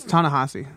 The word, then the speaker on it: Ta-Nehisi: Ta-Nehisi or Ta-Nehisi? – It's Ta-Nehisi. I'm pretty sure that's the way that I've heard it pronounced in the store.